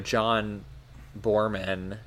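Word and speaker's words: Boorman: You know, John Borman. Is that how you pronounce his name? – Yeah.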